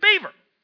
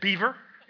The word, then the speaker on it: Bieber: I'm sorry, that's better than Justin Bieber. Right? Is that his name? Did I say it right? Who? – Bieber. – That's what I said, Beaver.